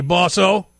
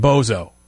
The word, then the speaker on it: bosso: Hey, bosso. It's spelled B-O-S-S-O. It's not it's not like Tony Montana saying bozo.